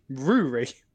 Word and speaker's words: Ruaidhri: R-U-A-I-D-H-R-I. Pronunciation. There we go. Apparently, Rory. – Rory. – Or Rory. – Rory. – Every single part of that name is silent. The D is silent.